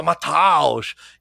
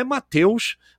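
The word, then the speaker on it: Matthäus: Que o Galvão Bueno enchia a boca para falar Mataus. E a pronúncia, a leitura com A, a com trema em alemão faz o som de E. Então é Mateus, Mateus, né?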